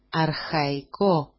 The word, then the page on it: arxaiko: arxaiko-